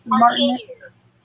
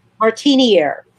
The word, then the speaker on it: Martiniere: And correct me if I, did I say that right? – Martineer. – I'm sorry? – Martineer. – Martineer. Okay, good.